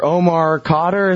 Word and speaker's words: Omar Khadr: All right, now, number two on your list here, Omar Khadr, is that how you say it? – Yeah, yeah, the Canadian, yeah.